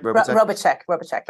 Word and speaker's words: Robitschek: And it was described that no one was more qualified than these nurses to assist Robitschek. Is that, did I say that right? – Robitschek, yeah.